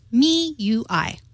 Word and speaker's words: MIUI: MIUI is correctly pronounced MIUI. – MIUI.